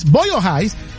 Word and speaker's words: Boyle Heights: En el sector de Boyle Heights, ¿cómo se dice? – Boyle Heights,